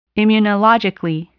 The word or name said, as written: immunologically